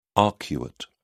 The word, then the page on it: arcuate: British English